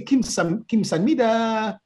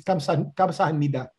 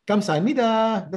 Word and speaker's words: Kamsahamnida: I say Kim Sanmida. The right way to say it, the correct way is Kamsahamnida. Kamsahamnida. That's thank you in Korean.